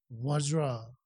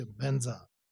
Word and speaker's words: Vajra: Окей, so Vajra, Tibetan master would say Benza. Doesn't matter.